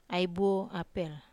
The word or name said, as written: apel